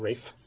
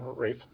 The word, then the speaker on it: Ralph: The proper pronunciation of the name is Fine, I think. Mm-hmm. As in Rafe Fine. Didn't Ralph Fiennes pronounce his name Rafe Fine? – I believe that may well be correct.